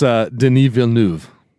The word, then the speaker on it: Denis Villeneuve: It's Denis Villeneuve. – Oh, God. So this was directed by Denis Villeneuve. I don't know. I don't know how to pronounce it. Your guy's name's Dennis, not Denis. – It's Denis Villeneuve.